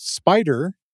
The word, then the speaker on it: Spyder: It's titled Spider, that's spelled S-P-Y-D-E-R, Your IDE for Data Science Development in Python.